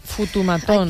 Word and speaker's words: fotomatón: A veure, si diem foto, no passa res, però si ho naturalitzem i té tradició, fotomatón. Es neutralitzen les os.